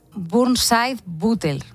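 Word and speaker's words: Burnside-Butler: Y un síndrome llamado, a ver si lo digo bien, Burnside-Butel, ¿no? – Sí, es un poco complicado de pronunciar, pero sí, es ese.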